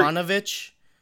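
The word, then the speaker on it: Yovanovitch: So there was a Washington Post article, and I forget what day it was, but it was after the Yakunovich. How do you say her name? She was the former Yovanovich.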